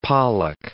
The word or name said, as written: pollack